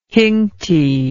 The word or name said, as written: hincty